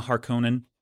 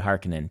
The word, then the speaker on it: Harkonnen: We get the moment where he's like, now I understand we are, you know, I'm a Harkonnen and, or Harkonnen, they say Harkonnen in the movies. That's not how I ever pronounced it.